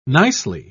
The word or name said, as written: nicely